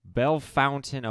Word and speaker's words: Bellefontaine: Today, our guest is coming to us from Belle Fountain, Ohio. It's spelled Bella Fontaine, but, you know, Belle Fountain's how it's said.